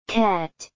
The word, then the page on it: cat: [kæt]